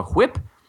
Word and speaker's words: whip: or they'll be talking about uh, driving some kind of, uh, I believe they call it a whip. Uh, Thank you for pronouncing the H.